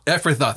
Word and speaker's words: Ephrathah: Ephrathah, thank you.